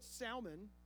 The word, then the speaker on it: Salmon: Nashon, the father of Salmon, that's the way you pronounce it. We say Salmon. Salmon is his name.